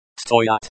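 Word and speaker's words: stoyat (стоят): The stress in chasu. Chasy is on the second syllable. The stress in krasivye. Krasivye is on the second syllable. The stress in stoyat. Stoyat is on the second syllable.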